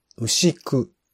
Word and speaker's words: 牛久: えー、っとですね、私からは、えー、牛に久しいと書いて、牛久というドキュメンタリー作品ですね。牛久といえば、まあ大体、あの、 120 メートルぐらい高さのある牛久